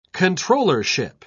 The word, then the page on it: controllership: British English